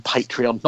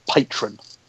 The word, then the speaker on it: Patreon: Luke, can you please learn that there's an E in Patreon, not patron? – I said Patreon earlier on in this podcast. – Last week you said patron, and it's not.